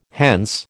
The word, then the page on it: hence: American English